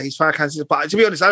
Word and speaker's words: Farrakhan: Louis Farrakhan, was it Farrakhan? – Louis Farrakhan.